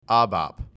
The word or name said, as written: ABAP